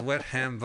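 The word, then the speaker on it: Wexham: As a matter of fact, it's in Wetham, Buckinghamshire. – It's pronounced Wetham, Buckinghamshire. Oh, no, you got it right.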